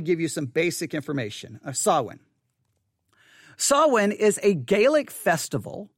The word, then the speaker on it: Samhain: So I'll let you hear how you're supposed to say it. – Samhain. – One more time. – Samhain. – One more time. – Samhain. – Samhain. All right? The word Samhain. Now, here's what I'm going to do. Okay, the word is Samhain. Again, it's spelled S-A-M-H-A-I-N, but it's Samhain.